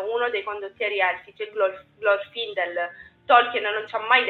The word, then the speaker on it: Glorfindel: io non riesco a dire la pronuncia, però mi dà la vita. Glorfindel. – Ecco, grazie. È quello di Gondolin che è morto,